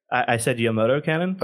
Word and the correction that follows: Yamato: Yeah, it's Yamato, I think, is the way it's generally pronounced.